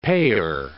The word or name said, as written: payor